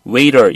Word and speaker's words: waiter: 요건 발음을 약간 좀 주의합시다. 요거는 좀 일반적으로 T를 거의 발음을 죽이기 때문에 그냥 waiter 이렇게 발음하시면 돼요.